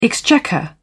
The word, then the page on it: exchequer: British English